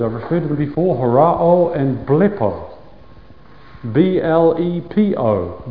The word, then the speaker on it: horao: But and the word for seeing here is horao. We get our word horizon from it. We've come across it before. H-O-R-A-O.